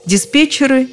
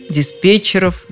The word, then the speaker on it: диспетчер: Эта история пусть и недавняя, а с ударением все просто во множественном числе оно никуда не уходит с корня. Там и остаются диспетчеры, диспетчеров, диспетчером и так далее.